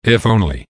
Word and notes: if only: American English

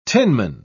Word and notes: tinman: British English

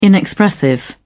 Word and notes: inexpressive: British English

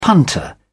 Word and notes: punter: British English

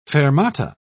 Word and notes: fermata: British English

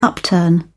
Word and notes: upturn: British English

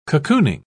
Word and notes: cocooning: British English

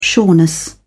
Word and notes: sureness: British English

